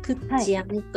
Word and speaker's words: 倶知安: あのクラブの句に、はい、知るに安心の安と書いて、クッチアンと呼びます、